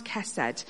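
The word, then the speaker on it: chesed (חסד): It's spelt H-E-S-E-D if you translate it into English, but it's pronounced chesed.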